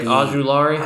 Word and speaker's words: Ojulari: Aziz Ojulari, right? Ojulari? – I believe that's correct.